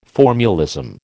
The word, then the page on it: formulism: American English